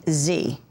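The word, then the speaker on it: Xe: Blackwater will now operate under the name Z, that's spelled X-E, but pronounced like the letter Z.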